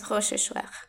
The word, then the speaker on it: Rochechouart: En el bulevar ese, no sé cómo se pronuncia, Barbe de Rochechouart. – Barbe de Rochechouart. – Bueno, ese mismo.